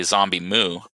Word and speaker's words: ZomBmu: Okay, so the correct pronunciation, most people call me Zombie. The full pronunciation would be ZomBmu.